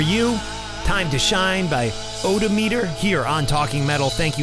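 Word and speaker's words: Odometr: I think od- odometer, right, Steve? I, I think that's how it's pronounced.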